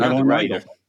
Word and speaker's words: Madeleine L'Engle: Madeleine L'Engle.